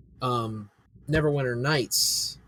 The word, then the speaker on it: Neverwinter Nights: They're re-releasing Neverwinter Nights.